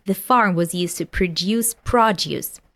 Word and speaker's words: produce: In this case, to produce, it's a verb. So we have the stress in the second syllable, to produce. And produce is noun, so the stress falls on the first syllable.